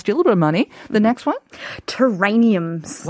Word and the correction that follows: terrariums: What did I what? say that right? No terrariums. Terrariums.